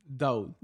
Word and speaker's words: Daoud: By the way, Duad. Did I say it right again? – Daoud. – That's what I said!